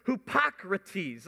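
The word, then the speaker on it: hypocrites (ὑποκριτής): It's pronounced hypocrites. Let me hear you say hypocrites.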